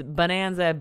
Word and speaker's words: Bohnanza: And if you want to find them, it's Bonanza, B-O-H-N-A-N-Z-A. Yeah. It's a little German pun there because bone is bean in German. – Bone. Is it pronounced bone or is it bon? – No, it's bone.